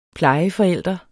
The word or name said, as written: plejeforælder